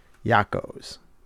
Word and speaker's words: Yocco's: It's not J-A-C-Q-O-S. It's surprisingly Y-O-C-C-O-S. Yakos. And it's because the Pennsylvania Dutch people that lived here couldn't pronounce the man's name. It was Iacocca. And they saw it and they just pronounced it Yakos.